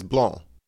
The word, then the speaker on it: Blanc: Like people say Sauvignon Blanc, mm-hmm, and pronounce the C at the end. That drives me nuts. – Okay, is Blanc. – The C is silent.